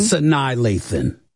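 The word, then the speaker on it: Sanaa Lathan: Sana Lathan. – Sanai Lathan.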